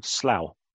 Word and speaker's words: Slough: There's a place just near High Wycombe, between High Wycombe and the airport, that is called Slough, Slough.